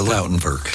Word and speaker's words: Lautenwerk: The Lautenwerk. – The Lautenwerk? – Yes.